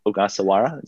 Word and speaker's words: Ogasawara: So yeah, it's very close to the Spanish type of pronunciation of the phonetics, but yeah, Ogasawara is very good.